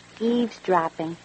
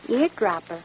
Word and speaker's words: eavesdropping: How do you say the word? – Eavesdropping. – And I said? – Eardropper.